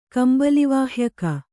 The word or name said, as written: ಕಂಬಲಿವಾಹ್ಯಕ